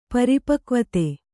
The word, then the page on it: ಪರಿಪಕ್ವತೆ: Kannada